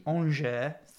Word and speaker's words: Angers: So most recent results, they beat Angers 3-2. – Oh, well pronounced, sir. – Angers. – Most of us would say Angers.